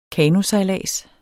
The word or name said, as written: kanosejlads